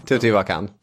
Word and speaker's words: Teotihuacan: Ja, det här är Nationalencyklopedin, vi fick slå upp uttalet. – Ja, men någonting i stil med Teotihuacan. – Teotihuacan.